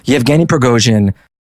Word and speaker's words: Yevgeny Prigozhin: Let's start back from the beginning though, and I will probably mispronounce this name like I mispronounce everything, but Yevgeny Prigozhin.